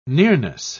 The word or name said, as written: nearness